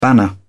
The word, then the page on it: banner: British English